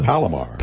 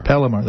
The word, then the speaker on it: Palomar: What's the Palomar. – Palomar.